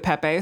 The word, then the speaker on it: Pepe: The original author of Pepe the Frog pronounced it Pepe, so now I am officially changing.